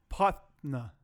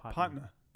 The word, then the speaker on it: patna: It's spelled P-A-T-N-A. But when you spell it out, like if you're trying to say it, how do you do that? What's the word for that? – Elongated? – Phonetically. When you spell it phonetically, it's P-O-T-N-U-H. – Potna. – No, P-O-T-N-U-H.